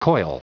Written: coil

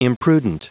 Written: imprudent

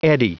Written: eddy

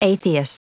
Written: atheist

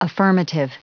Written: affirmative

